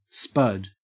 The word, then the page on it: spud: Australian English